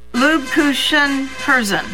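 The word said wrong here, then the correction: Lebkuchenherzen: Lebkuchen Herzen.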